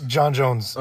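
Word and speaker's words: J'onn J'onzz: John Jones. That's the name, right? John Jones? – John Jones.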